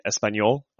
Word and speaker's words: Espanyol: Moving over to La Liga, we see that Real Madrid beat Espanyol. I think I'm pronouncing that right. – Yes, you got it.